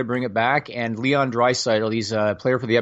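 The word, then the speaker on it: Draisaitl: Jay Lane says Jimmy Dresaltel was winning the Hart Trophy, right? – Who? – I don't know how to pronounce it. – Oh, Dreisaitl. Dreisaitl.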